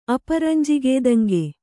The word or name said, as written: ಅಪರಂಜಿಗೇದಂಗೆ